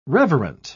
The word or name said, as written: reverent